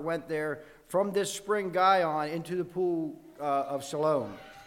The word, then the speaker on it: Gihon: It was spelled G-I-H-O-N, Gihon, I believe is how it's pronounced.